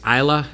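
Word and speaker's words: Ajla: She lost to Isla is how you pronounce her first name, Tomjanovic.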